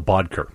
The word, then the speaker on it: Boedeker: Is it Boedeker? – No, Michael Boedeker. – Boedeker.